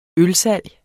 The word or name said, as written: ølsalg